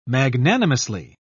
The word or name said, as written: magnanimously